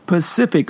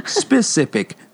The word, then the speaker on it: specific: There's the Atlantic Ocean and the Pacific Ocean, but some of you pronounce specific as Pacific. The word is specific. The ocean is Pacific.